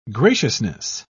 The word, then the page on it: graciousness: British English